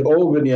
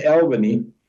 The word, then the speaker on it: Albany: And I must say, the 2 years that I've spent in Albany, and that is a giveaway, the fact that you said Albany and not Albany, because I almost didn't got to Albany when I arrived in the U.S., because typical South African, I pronounced it as Albany, and the guys just looked at me whether I'm totally lost.